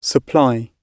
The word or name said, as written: supply